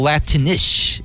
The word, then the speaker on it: Latinx: So if I want to really digress further, there is no such thing as Latinish or Latinx, as some of you pronounce that letter X. I pronounce the letter X as ish, like Mexica. So Latinish is how I pronounce Latinx, okay?